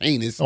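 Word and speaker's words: Ginés: G-I-N-E-S, but it's pronounced Heinous.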